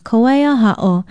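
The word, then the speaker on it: Kawaiaha'o (Kawaiahaʻo): Aloha, I'm Nilani Poliahu of Hawaii Public Radio, and our Hawaiian word of the day is a beautiful Ahu place name, Kawaiaha'o.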